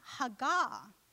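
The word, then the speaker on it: hagah (הגה): it's pronounced hagah.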